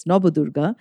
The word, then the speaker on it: Nabadurga: You know, we have the Nabadurga, the nine forms of goddess Durga, also called Nabadurga, but as a Bengali, I pronounce it as Nabadurga.